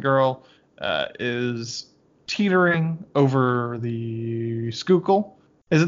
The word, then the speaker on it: Schuylkill: It is the Schuylkill. – It's good the Schuylkill. About to fall in. – You're so proud to say that. – I'm so proud.